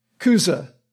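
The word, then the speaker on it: Chuza: In Greek, the CH is pronounced with a K, pronounced K, Cusa.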